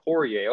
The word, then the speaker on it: Poirier: I've never even Poirier. – heard of this dude. Poirier, okay.